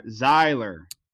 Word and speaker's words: Zahler: It's Z-A-H-L-E-R. Zahler. – Yeah. – Pronunciation. So today, everybody, we are talking about... Hold on. We are talking about this man. S. Craig Zahler. So it's like xylophone, but Zahler.